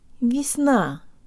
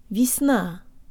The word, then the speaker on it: весна: de-emphasize the first syllable. It's not весна, but джо танг ліпс, весна, весна, весна, весна, весна.